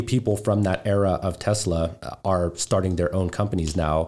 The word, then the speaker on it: Tesla: I literally I had a Tesla pitch deck because you know, they were mispronouncing the name. They're like, oh, is this a Telsa?